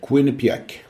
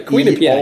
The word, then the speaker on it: Quinnipiac: Det får du uttala. Vad hennes universitet heter? – Quinnipiac. – Quinnipiac. – Piac. – Piac. – Det är ett Quinnipiac. I med där. – Just det, Quinnipiac. – I-A-C på slutet.